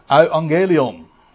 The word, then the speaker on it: Euangelion: Euangelion.